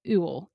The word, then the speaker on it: Ewell: So he is married to Kayla Newell, not Newell, Ewell, Ewell. I don't know how to say it. E-W-E-L-L, who played in Vampire Diaries, Matt Donovan's sister.